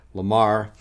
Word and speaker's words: Lamoureux: I believe that's how you pronounce his last name, L-A-M-O-U-R-E-U-X, Lamar.